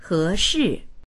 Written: he2shi4

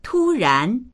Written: tu1ran2

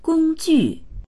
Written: gong1ju4

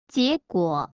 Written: jie2guo3

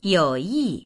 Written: you3yi4

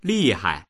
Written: li4hai

